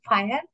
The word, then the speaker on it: FHIR: So you can spell it FHIR. You can pronounce fire, but the spelling is FHIR, Fast Healthcare Interoperability Resource.